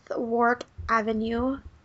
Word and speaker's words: Warwick Avenue: I want to be And that was Audrey Lately 13 with Warwick Avenue.